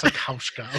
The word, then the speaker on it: Wasikowska: Mia Wasikowska, is how you pronounce that? – Wasikowska. Okay. Yeah. I feel like I was close.